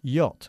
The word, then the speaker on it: yacht: Por cierto, pronunciamos yate en inglés, yacht. – Yacht. – No, y, yacht. – Yacht. – Y, como hielo. Yacht. – Yacht. – Yacht. Muy bien.